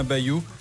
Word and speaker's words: Bayou: Le porte-parole des Verts, Julien Bayou, ça se prononce comme Bayrou quand on n'a plus de dents, a annoncé sa radiation.